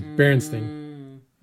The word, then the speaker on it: Berenstain: They always pronounced it sort of ambiguously. It was Berenstain. That you don't enunciate and you don't put the emphasis on that last syllable.